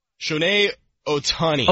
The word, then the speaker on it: Shohei Ohtani: Onahai? – Shohei Ohtani. – Ohtani.